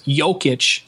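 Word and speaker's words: Jokić: And I did listen to the Denver broadcast, and they did say, I think I actually messed this up just now, but it's Jokic, like, yo, what's up, and Laverne instead of Lavernier, like I've been mispronouncing all season long.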